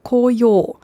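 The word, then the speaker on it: Koyo (こうよう): Die japanische Lesung ist Momiji. Momiji. Die chinesische Lesung ist Koyo.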